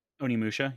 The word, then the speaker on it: Onimusha: Onimusha. – Onimusha, yeah.